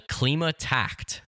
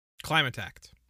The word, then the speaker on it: Klimatakt: It's called the Klimatakt. – Klimatakt. – Klimatakt. – Mm-hmm. Like climate, because it has to do with the weather. – Right. That makes sense. I thought they were pronouncing it Klima, but...